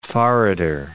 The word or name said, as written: forrader